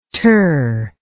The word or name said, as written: ter